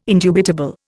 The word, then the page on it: indubitable: Indian